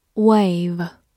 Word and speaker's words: wave: Wave, W A V E, wave，字母 a 发它本身的音 a，v 注意咬住下嘴唇 v，末尾的 e 不发音。Wave，它是一个动词或者名词，表示挥手、招手。比如说，wave